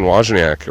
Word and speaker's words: Wojcicki: And like, man, I didn't like seeing Susan Wojcicki, or it rhymes with whiskey. Wojcicki, according to Charlie Rose.